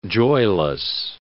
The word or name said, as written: joyless